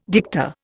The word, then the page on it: dicta: Indian